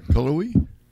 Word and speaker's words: Cullowhee: Just spell it out. I'll pronounce it for you. I got that kind of thing. – C-U-L-L-O-W-H-E-E. – You're going to have to go a little slower than that. Go ahead. What now? – Cullowhee. – C-U-L-L-O-W-H-E-E. – That sounds right. Cullowhee? – Well, I mean... – Is that what we're going with? – Yeah. – We're going with Cullowhee? – Cullowhee, North Carolina.